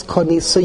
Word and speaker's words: konisa: On the day that you acquire the field from Naomi, we ignore the yud, even though it's spelled in the pasuk konisi, I have acquired. We read it as if it says konisa, you have acquired.